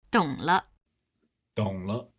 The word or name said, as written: dong le